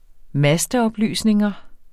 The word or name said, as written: masteoplysninger